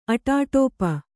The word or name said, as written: ಅಟಾಟೋಪ